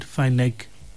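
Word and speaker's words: finagle: The word that we were looking for today is the word fineg, or fineg, fineg. Its spelling is very, very different from the way it is pronounced. It's pronounced as fineg, but it is spelled F-A-I-N-A-I-G-U-E.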